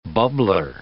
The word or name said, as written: bubbler